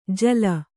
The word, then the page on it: ಜೋಲಿ: Kannada